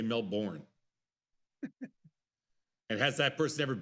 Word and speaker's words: Melbourne: And my Taiwanese sources say you butchered Melbourne. It's Melbourne. – What'd I say? – You said Melbourne. – Melbourne? – It's more like Melbourne.